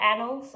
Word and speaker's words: annals: So, you know, it's like A-N-N-A-L-S. – Okay. Right? – So, I mean, it would make sense that it's annals, but I was mispronouncing that for a very long time.